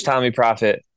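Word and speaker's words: Tommee Profett: Across the board, you just search Tommy Prophet.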